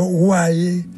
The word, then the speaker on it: vae: Vae derivó después en vae, pronunciado guay en latín clásico, y era el lamento que expresaba dolor, sufrimiento o pesar.